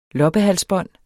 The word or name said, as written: loppehalsbånd